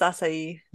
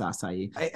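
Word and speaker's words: açaí: I believe it's acai. – It is acai.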